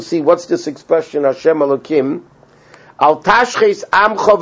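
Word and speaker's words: Elokim (אלוקים): And if you notice, the Elokim is written Yudke Vavke, but it's pronounced Elokim.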